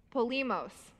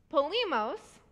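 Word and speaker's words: Polemos (πόλεμος): Polemos. Polemos is where the word politics comes from.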